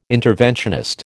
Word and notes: interventionist: American English

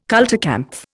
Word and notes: kulturkampf: Indian

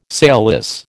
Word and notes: sailless: American English

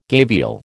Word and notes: gavial: American English

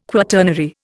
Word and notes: quaternary: Indian

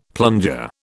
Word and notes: plunger: British English

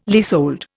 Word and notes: leasehold: Indian

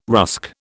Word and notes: rusk: British English

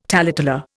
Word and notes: taleteller: Indian

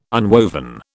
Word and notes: unwoven: British English

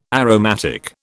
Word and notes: aromatic: British English

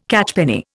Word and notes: catchpenny: Indian

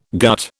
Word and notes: gut: British English

